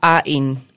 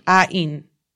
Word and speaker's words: Ain: I'll go ahead and say that the one I think is the cutest is named Ain or Ain. A-I-N. – Let's see if they have it. No, they don't have it written in Korean, so I can't tell you how to pronounce it. – He's cute. Ain. Well, it says Kim Ah-in. – Ah-in.